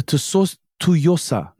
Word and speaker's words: Tsuyosa: Is it Tussoya? Is that how it's pronounced? Find the name right here. But the Tussoya is on Japanese pronunciations. – Yeah, I'm probably butchering that. But they came out with the they recently had a lot of success with the Tussoya. Yes, the Tsuyosa. Tsuyosa.